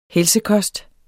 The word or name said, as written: helsekost